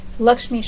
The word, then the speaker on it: Lakshmi: V-I-A-N-C-C-I? – C-H-I. – C-H-I. – Lakshmi Shah. – How do you spell that? – L-A-K-S-H-M-I. – Okay. – S-H-A-H. – H-A-H.